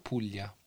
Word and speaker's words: Puglia: Wird es auf Italienisch ausgesprochen? Puglia, Puglia.